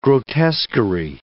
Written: grotesquerie

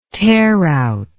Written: tearout

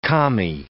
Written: commie

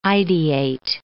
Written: ideate

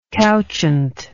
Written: couchant

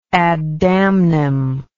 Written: ad damnum

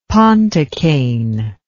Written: pontocaine

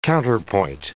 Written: counterpoint

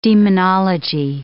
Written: demonology

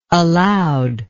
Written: allowed

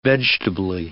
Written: vegetably

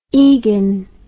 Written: egan

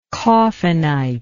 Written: coffinite

